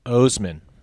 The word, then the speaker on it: Osman: How do you say that guy's name? – Osman.